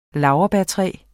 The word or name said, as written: NOBILIS